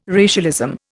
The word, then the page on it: racialism: Indian